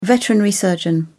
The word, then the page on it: veterinary surgeon: British English